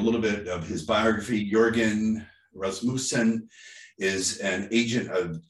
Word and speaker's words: Jørgen: However, our guest today, Jørgen, am I saying that right? It's a Norwegian name. – Pretty close. – Jørgen Rasmussen is a powerful agent for personal change.